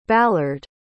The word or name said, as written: ballard